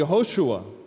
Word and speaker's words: Yehoshua: It's Joshua, or more accurately, Yehoshua. And can you hear that first syllable? Yeah, Hoshua. Yah, the name of God.